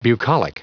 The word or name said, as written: bucolic